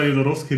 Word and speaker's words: Jodorowsky: Aber das übrigens war Jodorowsky richtig, ich habe es gegoogelt.